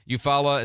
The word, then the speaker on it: Eufaula: How do you say it? Eufaula, Alabama, is that how you say it? Okay. Eufaula is 9.5 inches, the airport there.